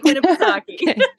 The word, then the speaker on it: Winnipesaukee: Winnipesaukee. Am I saying that right? Winnie the Pooh? – You got it, girl. Lake Winnipesaukee.